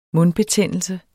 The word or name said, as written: mundbetændelse